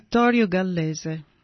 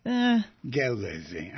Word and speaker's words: Vittorio Gallese: How am I doing? He's a neuroscientist at Parma. – So and so. – How was he? How would you pronounce? – Vittorio Gallese. – Where did I miss? Gallese. I got you.